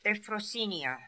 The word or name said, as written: Ephrosinia